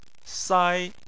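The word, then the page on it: sai: tone 1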